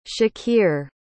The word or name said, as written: shakir